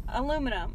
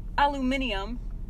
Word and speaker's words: aluminium: Can I get some aluminum? Like, aluminium. That's, like... Three extra syllables.